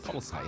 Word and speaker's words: Polisar: Polisar, yes, how you say it.